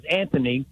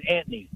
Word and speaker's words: Anthony: So I have a friend, his name is Anthony, and all the millennials call him Anthony. And I don't understand why they can't pronounce the T. The T is incredible, and that drives me crazy, just like it does Bubba.